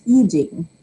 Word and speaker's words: I Ching: Views of the future as provided by our guest tonight by interpreting the Book of Changes, the ancient Chinese divination text known as the Yi Jing, or I-C-H-I-N-G, still pronounced Yi Jing.